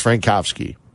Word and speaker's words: Frankowski: I want to say it's Premislaw, and his last name is Frankowski. There's a W, but you pronounce it as a V, as you do with Schweinsteiger. There's a W. It's pronounced as a V, just informing everybody.